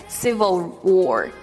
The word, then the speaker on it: civil: Guys, do not do not pronounce it like civil, civil. It's wrong, okay? Civil war, civil war.